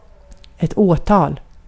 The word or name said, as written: prosecution